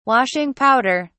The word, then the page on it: washing powder: British English